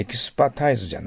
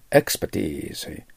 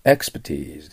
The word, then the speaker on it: expertise: ただ発音に気をつけてください。エキスパタイズじゃないんですよね。はい。 expertise。 expertise です。頭にアクセントがある。